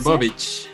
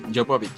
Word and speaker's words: Jovovich: Jovovich, yo no sé cómo se pronuncia. Jovovich, Jovovich.